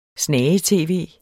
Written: snage-tv

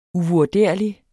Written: uvurderlig

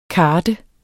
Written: kart

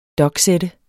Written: doksætte

